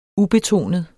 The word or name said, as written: ubetonet